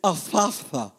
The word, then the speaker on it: Ephphatha: And looking up to heaven, he sighed and said to him, Ephphatha, Ephphatha. This is one of those words that it's impossible for English-speaking people to pronounce, but it's Ephphatha.